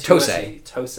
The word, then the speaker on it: TOSE: So this is made by a company called Tose. Am I saying that right? – Tose. They're an interesting company.